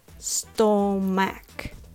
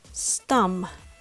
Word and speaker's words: stomach: You might be tempted to pronounce it stomach, but be aware this spell does not help you to pronounce it correctly. Let's learn how to say it. The first syllable is pronounced stum with the U sound like stuff, stuck, or study.